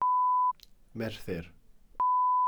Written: merthyr

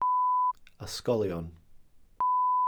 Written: ysgolion

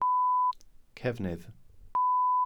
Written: cefnydd

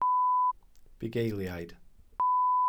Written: bugeiliaid

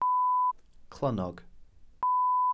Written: clynnog

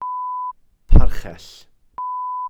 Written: parchell

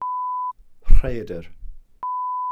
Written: rhaeadr

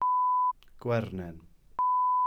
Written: gwernen

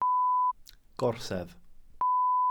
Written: gorsedd

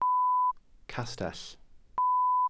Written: castell

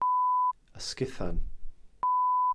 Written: ysguthan

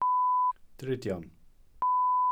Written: drudion

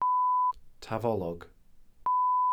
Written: tafolog

